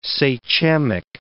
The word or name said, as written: sachemic